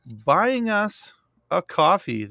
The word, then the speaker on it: Ko-fi: It's spelled Ko-Fi, but they pronounce it coffee.